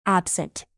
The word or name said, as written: absent